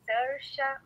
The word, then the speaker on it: Saoirse: O nome dela é Sersha Ronan.